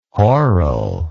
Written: horal

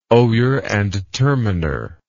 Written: oyer and terminer

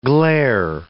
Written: glaire

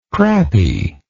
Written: crappy

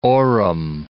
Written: Orem

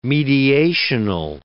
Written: mediational

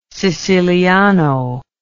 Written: siciliano

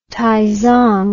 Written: taizong

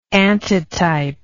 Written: antitype

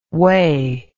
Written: Wey

